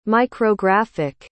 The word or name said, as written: micrographic